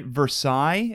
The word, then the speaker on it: Versailles: And they went to, isn't it Versailles? Is that how you pronounce it? – I believe so. – I think so.